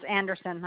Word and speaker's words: Anderson: A-L-V-I-N-A. Oh, sorry, it's Anderson. I have two last names. A-L-V-I-N-A, and it's Anderson, A-N-D-E-R-S-O-N.